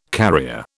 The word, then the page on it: carrier: British English